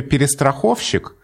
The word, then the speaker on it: перестраховщик: А вот в слове «перестраховщик» ударение сразу установилось на «о», потому что «перестраховщик», 3 безударных, а потом ударный, говорить очень неудобно. Поэтому ударение сразу установилось на «о», и в этом слове «перестраховщик» вообще колебаний не было.